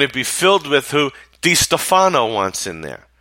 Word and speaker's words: DeStefano: And I think I said Stefano in the live short I did yesterday, but it's John DeStefano that is in charge of this personnel situation.